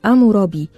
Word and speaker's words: حمورابي: مين بقى هو الملك حمورابي، ملك البابليين؟ الملك حمورابي ده هو سادس الملوك في العصر البابلي، وحامورابي اسمه باللغة الأكادية بينطق أمورابي، ومعناه في اللغة الأكادية المعتلي أو المعتلي الحكم أو العرش.